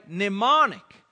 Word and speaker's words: mnemonic: And this is called a mnemonic. Mnemonic. And it's spelled funny. It's with a M. M-N-E-M-O-N-I-C.